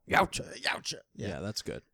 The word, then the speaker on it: Yautja: Yautja, yeah.